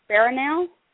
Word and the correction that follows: Berlinale: Barnall? the talent campus? Did you take classes out there too recently? – Oh, yeah, the Berlinale talent campus.